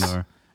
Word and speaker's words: SoGnar: SoGnar. And it's S-N-O, or S-N-O, SnoGnar? – SnoGnar. – Silent N, though. Silent N. Silent G, silent N, SoGnar.